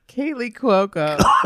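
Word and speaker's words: Kaley Cuoco: Which with Kelly Cacao. Is that how you say it? Kelly Cacao? Kelly, what's her name? – I can't even say it. Kaylee Cuoco.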